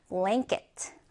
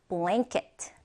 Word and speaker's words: blanket: 자, 발음교를 잘 보면 blank. 약간 랭 발음이 들어가요. 그래서 blanket. blanket.